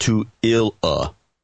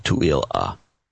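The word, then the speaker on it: Tooele: It's pronounced Tu-il-a. Okay, Tu-il-a.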